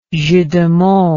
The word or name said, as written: jeu de mots